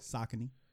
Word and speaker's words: Saucony: It's just like, it's like people don't know how to pronounce Saucony. Like, they say Saucony is pronounced Saucony. – Huh? You talking about Saucony's, like the shoes? Mm-hmm. It's pronounced what? – Saucony.